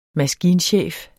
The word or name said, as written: maskinchef